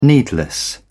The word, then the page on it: needless: British English